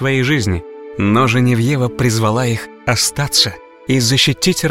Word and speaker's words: Женевьева: Святая Геновефа более известна нам как Женевьева. Так произносят ее имя на родине во Франции.